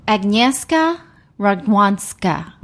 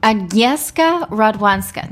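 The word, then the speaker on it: Agnieszka Radwańska: I think it's Agnieszka Radwanska. Mm-hmm. Agnieszka Radwanska. – That sounds exactly right. – Agnieszka Radwanska.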